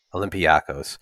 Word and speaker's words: Olympiacos: Olympiacos? – Oh, Olympiacos? – Olympiacos.